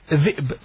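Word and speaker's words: zib (ذئب): It's pronounced zib. Try to pronounce it correct. – Zib, zib. – No, zib, zib. – Zib. – Yes, you just turn a little bit about on the hamza there.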